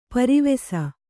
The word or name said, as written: ಪೆರ್ವಸ